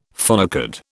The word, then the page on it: phonecard: British English